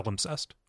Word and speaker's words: palimpsest: it's a palimpsest or I don't know if I'm pronouncing that word right. – Palimpsest or pedimentary.